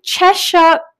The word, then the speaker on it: Cheshire: Brittny, are you based in Cheshire, Connecticut? – Yes, that is exactly how you say it.